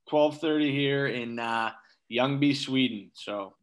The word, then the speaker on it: Ljungby: But it's pronounced Ljungby, I guess. You know, the L is silent.